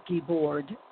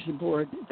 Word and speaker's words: Guibord: And it's Guibord, not Guibord. That would be very, very French. It is a Canadian-French last name. But if you think keyboard, just add the Guibord. You have the correct pronunciation of the name.